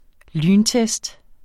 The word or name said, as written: lyntest